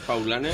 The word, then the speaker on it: Paulaner: Aquí es Paulaner.